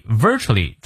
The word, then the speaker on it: virtually: If a man never contradict himself 啊，the reason 啊，这个理由 must be that 肯定是 he virtually virtually 注意这个音节的发音啊，重音在前。